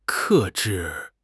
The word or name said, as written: 克制